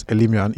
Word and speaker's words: Elimian: Okay, my surname is Elimian.